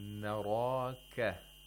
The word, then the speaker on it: naraka: For example, if you say nareka, You're pronouncing all the letters with thinness. – And if you say naraka, you're pronouncing all of them with tafkhim. – And again, it is wrong.